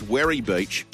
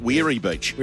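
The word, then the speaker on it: Werri Beach: Levy, can you please tell Piggy that it's pronounced Werry Beach, not Weary Beach? – Weary Beach.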